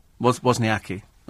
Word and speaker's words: Wozniacki: Is it Wozniacki? Wozniacki.